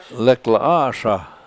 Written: lak7la7aSha7

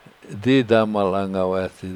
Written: didamal 7angaweesa

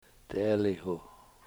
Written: t$eeliwhu

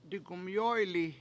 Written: digumyOyli7

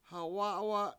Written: ha7wA7wa7

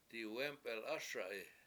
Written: diwEmpelASa7i

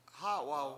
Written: hA7waw

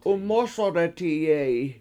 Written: 7umOsodetiye7i

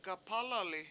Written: gapAlali

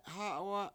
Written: hA7wa7

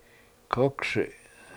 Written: kOkSi7

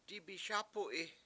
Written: dibiSAp$u7i

